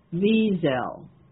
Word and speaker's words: Wiesel: It's like there's no accent on the second syllable, but it is a Z sound, not an S sound for in English, you know. It's a German, basically Yiddish German word, and S is pronounced like a Z, but the accent is equal on both syllables, Wiesel.